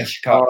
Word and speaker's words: Chikara: Is that how you say it, Shakara? – Yeah, Chikara, uh, Chikara, yeah.